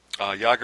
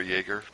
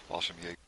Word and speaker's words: Fallschirmjäger: Jäger or Jaeger, it all depends, I suppose, Fallschirmjäger. – Jaeger is a filthy, awful alcoholic drink, so we're going to say Jäger.